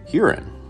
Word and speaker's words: Huron: And mostly the town is pronounced Huron, which of course sounds like urine.